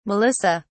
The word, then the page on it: melissa: British English